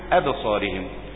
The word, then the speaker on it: أبصارهم: أبصارهم مش أب، أبصارهم، ترهقهم التاء مع الراء برضه عشان ما تفخمش، وقد كانوا يدعون إلى السجود وهم سالمون.